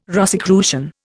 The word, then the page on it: Rosicrucian: Indian